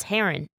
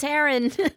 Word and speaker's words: Heron: Now I just realized I've been saying your name wrong this whole time. It's Heron. – It's Heron, yes. – Sorry, everyone. It's Heron.